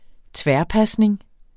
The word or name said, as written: tværpasning